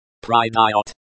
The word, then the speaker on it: Придёт: Придёт. Придёт, the stress falls on the second syllable.